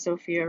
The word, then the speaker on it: Sophia: My name is Sophia Ramirez, and it's spelled with a PH. So thank you to everybody who spells it with an F.